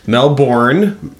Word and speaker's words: Melbourne: Yeah, so it's pronounced Melbourne.